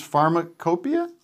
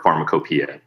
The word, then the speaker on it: Pharmacopeia: Are, uh, let me just say, as, if people don't know Hamilton, uh, your show, Hamilton's Pharmacopeia, is that how you say it? – That's how you say it, or Pharmacopoeia. Both are fine.